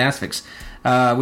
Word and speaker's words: Asphyx: He did the music for the Asphyx. Is that how you pronounce mm-hmm. that one? Yeah, the Asphyx, which is a fun little flick.